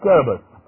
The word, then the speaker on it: Scarabaeus: B-E-E-T-L-E. No. Here's the name of this beetle. Scaraberus. S-C-A-R-A-B-A-E-U-S. Again, S-C-A-R-A-B-A-E-U-S. Yeah, it's Scarabus, but I have to spell it for you.